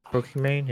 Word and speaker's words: Pokimane: Pokimane, yeah.